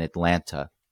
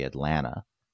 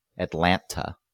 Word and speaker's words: Atlanta: Every week, every day of every week, you join a radio show that's on the air in Atlanta. I pronounced the second T, yes. I apologize because it's supposed to be Atlanta. Atlanta.